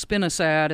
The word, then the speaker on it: spinosad: I thought you said spinosad. Could you spell that, please? – Yes, spinosad.